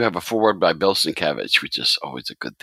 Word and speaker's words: Sienkiewicz: And at the top, it said, and I see this wherever I see him, Sin, and then in big letters, Kev, and Itch at the end, Sienkiewicz. And I said, oh, that's how you pronounce it.